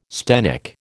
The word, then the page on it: sthenic: American English